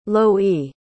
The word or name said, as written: loie